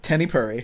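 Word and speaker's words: Tenny Puri (テニプリ): Tenny Puri. – Tenny Puri. – Yeah, it's like a tennis prince something. Like, they separate prince into two syllables.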